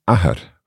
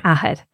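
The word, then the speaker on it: athair: So Ahar for father. – Ahar. Now, my father, since Ahar begins with a vowel, it's just going to be Mahar.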